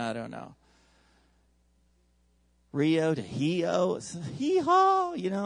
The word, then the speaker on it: Rio: And if you say it like the Portuguese, R's are H's. So I'm going to tell you how you say Rio in Brazil. It's Rio.